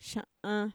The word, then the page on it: sha'an: Mixtec